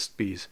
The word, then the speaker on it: Spies: So his last name is spelled like Spies, S-P-I-E-S. But I do know that in German language, if there's an I-E like that, it's pronounced, so his name would be pronounced Spies.